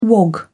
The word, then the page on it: wog: British English